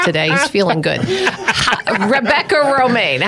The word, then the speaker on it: Romijn: Romijn. That's pronounced Romijn, like the lettuce, yeah.